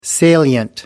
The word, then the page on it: salient: American English